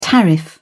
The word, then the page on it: tariff: British English